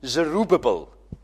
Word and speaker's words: Zerubbabel: And then this character who is mentioned more often, Zerubbabel. A very difficult name to pronounce, but I'm sure I'm doing it the right way, because Welsh has very close affinity to Hebrew.